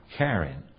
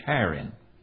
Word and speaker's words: Haran: All right, and he came out of the land of the Chaldees and dwelt in Charan. Charan. Some pronounce it with H, Haran.